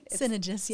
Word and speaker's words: Synagis: What about Synagis? Mm-hmm. Am I saying that right? – Synagis, yeah.